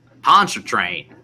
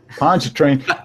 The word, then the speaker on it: Pontchartrain: Like you probably have an unusually quaint way of saying Pontchartrain. How do you say that? – Pontchartrain. – Pontchartrain. Like Pontchartrain.